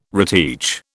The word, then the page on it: reteach: British English